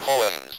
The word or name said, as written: poems